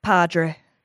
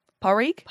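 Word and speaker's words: Pádraig: Padre. No, okay. Padraig? – Padre. – Padre. – Parik? – Parik. Parik. – Parik, yeah. – So you just don't pronounce the D at all.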